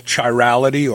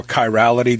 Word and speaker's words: chirality: The term that classifies this is called chirality or chirality, depending on how you pronounce it, C-H-I-R-A-L-I-T-Y.